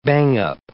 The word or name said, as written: bang-up